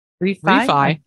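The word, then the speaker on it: Refy: I can't even imagine it's refi, like a home loan, but it's R-E-F-Y. How would you say that? – Refi? – I guess. – I know what you're saying. Yeah. Okay. – So apparently refi with refi.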